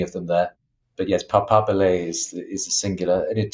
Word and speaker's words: papabile: Yes, it's papabile is the singular. Papabili is plural. That's the Italian.